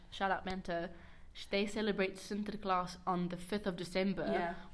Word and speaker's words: Sinterklaas: is Sinterklaas. – Yes, I was about to say that. – Yeah. I don't know if I'm saying... You probably know how to say it. – Sinterklaas. – Sinterklaas, yeah, because everyone, Talia speaks Afrikaans, which is...